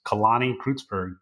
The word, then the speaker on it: Kalani Creutzburg: Just hit me up on Facebook uh, send me a friend request, Kalani Creutzburg, K A L A N I.